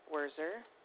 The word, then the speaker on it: Wurzer: My name is Janine Werzer, W-U-R-Z-E-R. Janine, J-E-A-N-I-N-E. – You said J-E-A-N-I-N-E? – Yes.